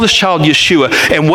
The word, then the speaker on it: Yeshua: And the name Jesus, as we pronounce it Jesus in English, if we were to say it in Hebrew, would actually be Yeshua.